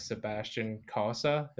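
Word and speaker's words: Sebastian Cossa: Keep your eyes on the future too for their goalie prospect, Sebastian Kosa, if I'm saying that right. – No, you nailed it.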